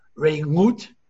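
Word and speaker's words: Re'ut (רעות): Re'ut is really supposed to be pronounced Re'ut. So how could Re'ut come root? So some say that in some communities, the ayin was not pronounced, and Re'ut could become root.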